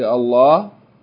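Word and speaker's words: الله: The rule is that whenever there's a fatta or dhamma before the word Allah, then it's pronounced heavy with tafkhim. You say Allah, like we said in Surah An-Nasr, إِذَا جَاءَ نَصْرُ اللَّهِ.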